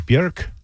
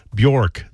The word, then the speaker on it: Björk: Unless you speak Icelandic, correct pronunciation is Björk. I'm not kidding, but you've been saying Björk this whole time.